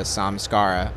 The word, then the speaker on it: samskara: Is it Samskara? – So a samskara, it's a Sanskrit word.